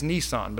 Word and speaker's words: Nisan: I heard the right way to say it's Nisan, but I've heard my dad say it so many times the other way.